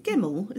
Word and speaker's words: Gemmell: And then Nikki, is it Gemmell? Is that how you pronounce her name? – Yeah.